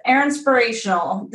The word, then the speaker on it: Erinspirational: So your business is Erinspirational. Did I say that right? – You did.